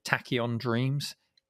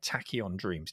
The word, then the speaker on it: Tachyon Dreams: And it's named, now correct me if I'm not pronouncing this right, John, is it Tachyon Dreams? – That's exactly right. – T-A-C-H-Y-O-N, Tachyon Dreams.